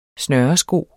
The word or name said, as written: snøresko